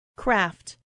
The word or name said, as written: craft